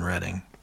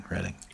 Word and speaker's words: Reading: And he said that it used to be around here, but the main one's in Reading. I said that right. Not Reading, Reading.